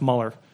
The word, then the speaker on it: Mueller: It's spelled Mueller, but it's pronounced Mueller, Bob Mueller.